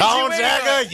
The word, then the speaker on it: Gonzaga: Gonzaga University.